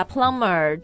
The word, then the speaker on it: plumber: 像水管 plumb 对吧 plumber 就是水管工那个 B 也是不发音的